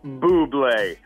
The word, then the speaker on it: Bublé: And he said, no, it's pronounced Bublé.